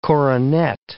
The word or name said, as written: coronet